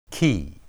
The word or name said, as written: ki